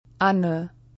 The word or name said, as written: Anne